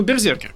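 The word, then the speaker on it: BRZRKR: Вот, комикс э, называется непроизносим, он называется Брзеркр. – Ну, Берзеркер, короче.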